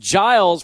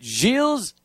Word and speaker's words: Gilles: We've been calling him Giles for 4 years or 3 years, however long it is, but it's good to know that Gilles DeConnick is the correct pronunciation of his name.